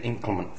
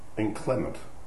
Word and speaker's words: inclement: And um, Although the weather's a bit inclement for barbecues at the moment. Inclement. Inclement. You've got the emphasis on the wrong syllable.